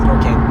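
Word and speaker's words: Norqain: Is it Norcane? – Yeah, that's how you say it. Yeah, it's Norcane.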